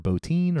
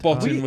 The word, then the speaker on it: Bottin: Well, I'm, I'm, assuming they probably both worked with with Rob was it Bottin or Botine or Botine? – Rob Bottin was like the Bottin, um, yeah.